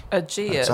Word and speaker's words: Aegeus: Next up is, I don't know how to pronounce this. It's pronounced A-E-G-E-U-S. I think it's kind of Greek. – Aegeus. – Let's have a look. – Aegeus, yeah, probably.